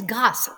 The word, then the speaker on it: Grasse: And also, I've always wanted to go to, and I hope I'm not going to pronounce this wrong, is it Grasse? – Yes, Grasse.